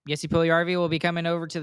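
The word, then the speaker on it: Puljujärvi: I think Paul Yuliarvi. Is that how you say his name? – Jesse Puljujarvi.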